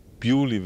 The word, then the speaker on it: Beaulieu: Nehmen wir jetzt mal in der Umgebung von New Milton haben wir in Beaulieu, was ausgesprochen wird, Beaulieu wird es ausgesprochen, dieses Beaulieu. Und